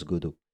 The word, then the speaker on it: Godot: Le deuxième moteur en tête arrive Godot, avec un T à la fin, mais on prononce Godot.